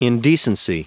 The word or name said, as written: indecency